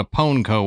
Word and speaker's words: Pwnco: It's P-W-N-C-O, but it is pronounced Pwnco.